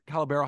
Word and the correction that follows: Caballero: How do you say his last name? – Caballero. Jose Caballero. Yeah.